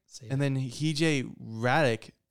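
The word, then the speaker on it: Hradek: Hardick. I don't know who they are, but they're for the NHL. What? – Radic. – Okay. – Oh, the H is silent.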